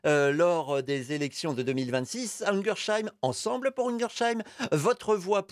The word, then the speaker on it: Ungersheim: Alors, on entend Ungersheim, mais ça s'écrit Ungersheim, U-N-G-E-R. S-H-E-I-M, je vais aller jusqu'au bout.